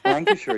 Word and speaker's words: Lancashire: Are you, did I pronounce Central Lancashire? – Lancashire, yes.